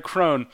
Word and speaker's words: Crone: I walk in, Greg Crone. The E is silent.